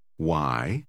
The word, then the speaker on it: why: Achten Sie darauf, dass das WH ausgesprochen wird. Lesen Sie das Wort Nummer 15 noch einmal. – Why? – Die Nummer 16.